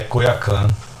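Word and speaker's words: Coyoacán: É, em Coia, Coia, Coiacan, como é, como é que pronuncia? É, Coiacan. Tá.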